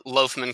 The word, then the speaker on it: Loughman: Uh, Loughman, kind of like a loaf of bread, but it's L O U G H. – Uh, M-A-N.